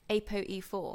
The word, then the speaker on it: APOE4: APOE4. Have I said that right? – Yeah.